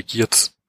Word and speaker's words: Giertz: Ich steht nur als Simone Giertz. Aber ja, ja der ist, spricht natürlich wahrscheinlich auf, ein bisschen Englisch Schwedisch, aus. Auf Schwedisch spricht sich das wohl Hirsch aus.